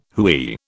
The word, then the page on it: hooey: British English